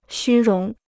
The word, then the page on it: 虚荣: xū róng